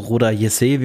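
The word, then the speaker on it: Roda JC: Genau, er war ausgeliehen an Roda Kek, also Roda Jesse, wie man sagt, in Deutschland halt als Roda Kek gerade bekannt.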